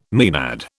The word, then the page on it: maenad: British English